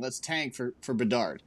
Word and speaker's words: Bedard: Am I saying his name right? Now I can't say it. – Connor Bedard. – I did say it right.